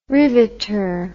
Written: riveter

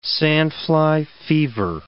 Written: sandfly fever

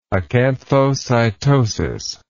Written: acanthocytosis

